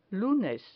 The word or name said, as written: lunes